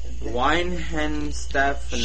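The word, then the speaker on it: Weihenstephan: Weinhenstephan. – Scheiße. If it's got a W, it's a V.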